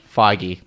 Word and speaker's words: Feige: Amy Pascal and Kevin Feige. – Feige? – Yes. – Do you pronounce it in the end? – Feige.